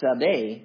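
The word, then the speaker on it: Tzadeh (צדי): What's really interesting about that letter is the letter is pronounced Tzadeh.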